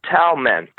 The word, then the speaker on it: Telmen: Telmen. T-E-L-M-E-N.